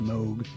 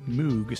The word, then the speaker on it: Moog: That would be the appropriate way to say it, but you know, moog is pronounced moog, but it's often mispronounced as moog, so my guess is they wanted you to say the cow says moog.